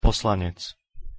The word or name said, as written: poslanec